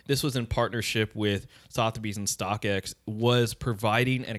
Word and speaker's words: Sotheby's: Like typically Sotheby's, and I pronounce that on point.